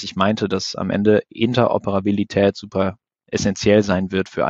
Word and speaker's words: Interoperabilität: Und du hast eben gerade schon richtig gesagt, Interoperabilität, schwieriges Wort, gut ausgesprochen.